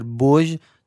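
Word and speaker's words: Bosz: اه، کلا آقای پتر تلفظ بوژ. – درست پتر بوژ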